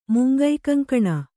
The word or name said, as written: ಮುಂಗೈಕಂಕಣ